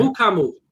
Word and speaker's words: Mukamu: Mukamu. Just Mukamu. – Really? – That's it. – Mukamu. All right. That's so it. The U is silent in the last name.